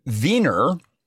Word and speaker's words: Wien: They call it Wien, which is spelled W-I-E-N, because Germans pronounce W's like V's.